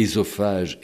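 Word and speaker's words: œsophage: Il insistait sur le fait que l'on devait dire Édipe et Ésophage, et non Œdipe et Ésophage, ce que disent tous les Français.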